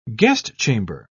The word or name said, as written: guestchamber